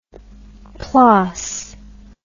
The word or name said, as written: plaas